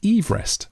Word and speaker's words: Everest: But the way you pronounce that family surname is Everest.